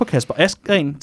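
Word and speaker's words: Kasper Asgreen: men jeg tror mere på Kasper Askren til Flandern eller Roubaix. Er det sådan, man udtaler ja, det? Ja.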